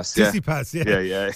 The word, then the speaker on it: Tsitsipas: And then, of course, I can't even pronounce the guy's name, the Greek lad, Tsitsipas or whatever. – Tsitsipas.